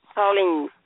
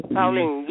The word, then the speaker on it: Pauline: Pauline. – Pauline. – Pauline, yes. – How do you spell that? – P-A-U-L-I-N-E.